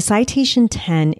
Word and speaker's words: Citation X: The citation 10 is written as citation X, but it's pronounced 10 like the Roman numeral.